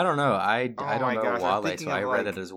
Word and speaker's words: Wale: Or Is There Any Love with Whale? – Yeah, it's pronounced Wale.